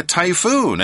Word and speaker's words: typhoon: Typhoon is spelled T-Y-P-H-O-O-N. It's that P-H-F-sound like we find in telephone or emphasis or something like that. So it's not T-Y-F, it's T-Y-P-H-O-O-N.